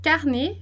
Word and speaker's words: carnet: Lembrando lá que o som do T do S e do D a gente não pronuncia no final de palavra. Então, T, S e D não pronuncia no final de palavra. A gente não vai dizer carnet, a gente vai dizer carnet, como no português.